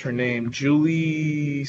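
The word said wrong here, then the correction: Jurnee: Smollett-Bell? Journey Smollett-Bell.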